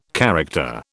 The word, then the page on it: character: British English